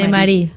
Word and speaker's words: Anne-Marie: Estamos charlando, ella es la directora de este documental que les estamos recomendando hoy, Anne-Marie, Anne, ¿no? Se pronuncia Anne-Marie. – Anne-Marie, sí.